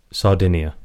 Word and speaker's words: Sardinia: So these little guys in Sardine, is it just called Sardine? – Sardinia. – Sardinia. – Oh. Sardinia.